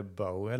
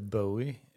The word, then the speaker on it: Bowie: Du kan uttala det Bowie eller Bowie. – Ja, man får eh, säga båda alltså.